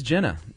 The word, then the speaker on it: Gina: Is it Gina? – It is Jenna. – Okay. – It is spelled Gina, and Jerry told me how to pronounce it.